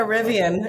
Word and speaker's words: Rivian: I got a car that rhymes with my name. Do you? – I got a Rivian.